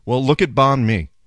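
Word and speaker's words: bánh mì: B-A-N-H. A has this little ramp going up. Well, that ramp means banh, like you're going up. And then mi going down is mi. Banh mi.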